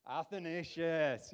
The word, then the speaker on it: Athanasius: A fourth century writer, Athanasius, I think I've said that right. Apologies if you're here in the crowd. What is it? – Athanasius. – Yes, there we go.